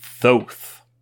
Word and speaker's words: Thoth: From the future here to tell you that apparently the most common pronunciation is Thoth.